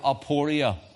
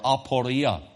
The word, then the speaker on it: aporia: The word perplexity is the word aporia or aporia, as some may pronounce it.